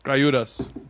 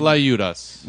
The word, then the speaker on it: Tlayudas: Tlayudas. Which is deceivingly spelled. – Tlayudas. – Much differently than how it sounds. – It's T-L-A-Y. – Yes, yeah. – Or certain regions spell it C-L-A-Y. – C-L-A-Y.